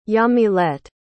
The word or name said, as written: yamilet